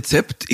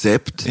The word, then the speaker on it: Zapped: Also äh, äh, wir sagen ja alle Zept, ich sage auch Zept. Eigentlich heißt es Sept ja. auf Englisch,